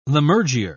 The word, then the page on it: lammergeyer: British English